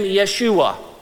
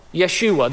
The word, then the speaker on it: Yeshua: Because they would have pronounced his name Yeshua. Yeshua, that's the name.